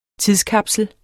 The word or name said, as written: tidskapsel